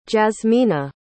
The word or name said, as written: jazmina